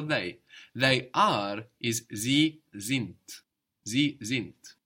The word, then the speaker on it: sind: Sind is spelled SIND. So we have the S pronounced like a Z there, and the D on the end of the syllable pronounced like a T.